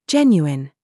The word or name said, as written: JUDGE